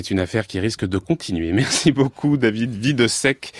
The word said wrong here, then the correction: Vodisek: Je rappelle que vous êtes Vodisek, excusez-moi, j'ai écorché votre patronyme.